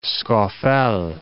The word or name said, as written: Sca Fell